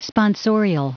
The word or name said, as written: sponsorial